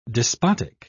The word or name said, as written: despotic